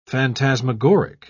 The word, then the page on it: phantasmagoric: British English